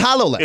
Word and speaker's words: HoloLens: It's not Halo. – It's HoloLens. – It's H-O-L-O-L-E-N-S. – Oh, it is spelled. All right, so it's not H-A, it's Holo.